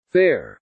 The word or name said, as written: fare